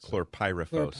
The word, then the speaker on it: chlorpyrifos: I can never pronounce chlorpyrifos. – Chlorpyrifos, yeah.